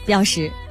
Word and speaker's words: 标识: 就念认识的识了，所以现在呢这个字就念标识，标识啊。